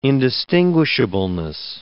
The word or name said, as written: indistinguishableness